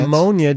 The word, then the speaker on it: pneumonia: Pneumonia does not need the P, Dane.